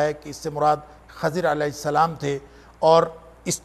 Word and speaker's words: خضر: جس کو عام طور پر خضر کہا جاتا ہے، اس کا درست تلفظ خضر ہے لیکن معروف خضر ہے۔